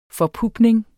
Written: forpupning

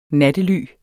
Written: nattely

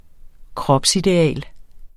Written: kropsideal

